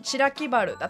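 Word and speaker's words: 白木原: だった、はい、福岡では地名の原の字を春と呼ぶことも多々あるんですが、白木バルだったり春日バルのようにバルと読むこともあるんですね。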